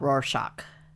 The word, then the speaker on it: Rorschach: We're going to talk today about Rorschach.